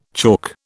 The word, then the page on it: chalk: British English